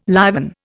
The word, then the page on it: liven: Indian